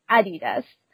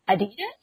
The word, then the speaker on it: Adidas: the European way, at least from my understanding, is Adidas. But I think from like people from American, I think they will pronounce Adidas.